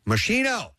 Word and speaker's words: Moschino: M-O-S-C-H-I-N-O. – Massimo? – Moschino. – Moschino. No, and that's not how you pronounce it. Moschino.